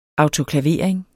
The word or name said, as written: autoklavering